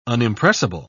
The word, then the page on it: unimpressible: British English